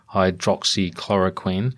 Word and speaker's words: hydroxychloroquine: And Kelly, of course, has been a big fan of hydroxychloroquine, if that is in fact how you say it.